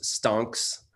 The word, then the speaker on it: stonks: I just want to say that it's pronounced stonks. Thank you. – Stonks, excuse me.